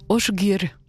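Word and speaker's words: Ásgeir: Asgeir, A-S-G-E-I-R. Semblerait qu'en fait ça se prononce Osgeir, un duo qui vient d'Islande entre Amy Margaret Seyfarsdóttir et Osgeir Einarsson.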